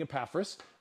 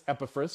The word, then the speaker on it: Epaphras: For years, I've been saying Epaphras, and I learned that it's Epaphras, so I'm going to try to say it right, but I may not say it right the entire teaching.